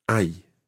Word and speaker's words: hay: Hay is spelt H-A-Y. Hay. – Hay. – Hay. – Hay. – When you see that word, it's quite tricky to remember that you don't pronounce the H. Ay.